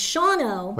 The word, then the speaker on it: Shawano: It's Shawano. – Which is S-H-A-W-A-N-O.